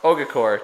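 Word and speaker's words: Ogakor: They have to Ogafor. Am I saying this right? – Ogacor.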